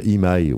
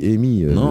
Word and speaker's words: EMI: Imaï, ouais, Imaï, mais en français on dit Amy, C'est une boîte américaine, on dit eh ben, Mais oui, mais la prononciation française s'impose sur le territoire, cher ami.